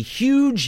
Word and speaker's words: Huge: At the Woodlands Tavern as part of the Huge, Huge Holiday Show. That's huge as in the first syllable of Eugene, but not to be confused with Eugene, who is also Adam Warrock.